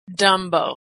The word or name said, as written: dumbo